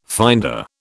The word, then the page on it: finder: British English